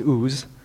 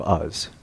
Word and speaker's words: Uz: So, but today's lesson is titled "The Father from Uz." It's not pronounced "Oz," as some people have said, or "Oz."